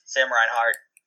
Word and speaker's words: Reinhart: All right, moving down to the lower end of the 6K range, Sam Reinhart. Did I say that right? – Yes, Sam Reinhart.